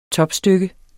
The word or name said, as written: topstykke